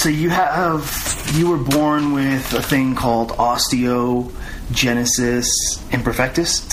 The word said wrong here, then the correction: imperfecta: Did I say this right? – Close. Imperfecta.